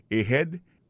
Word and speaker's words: ahead: Ahead. Ahead, là, c'est A-H-E-A-D. Ahead. Ahead. Go ahead. Là encore, on a vu que l'accent tonique l'a tombé sous deuxième syllabe.